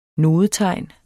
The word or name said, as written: nodetegn